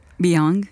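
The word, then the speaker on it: Byung-Chul Han: Terminé de leer un libro que se llama, ¿cómo se pronuncia? – Beyond. – Beyond. Beyond, el hombre. – Beyond se llama. Beyond Chulhan.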